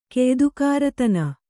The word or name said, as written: ಕೆಯ್ದುಕಾರತನ